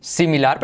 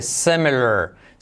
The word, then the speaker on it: similar: Similar, pero fíjate cómo se dice. Similar. Ay, como pongo la boca. Similar. Yes, similar.